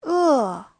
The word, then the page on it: e: e4